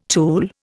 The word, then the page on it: tool: Indian